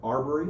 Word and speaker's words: Arbery: This is the story of the murder of Ahmaud Arbery. I'm not sure how you say his name. It's the story that... – Oh, that's the three guys that... Okay, yeah, it's Arbery. – Arbery? – Mm-hmm. Ahmaud Arbery.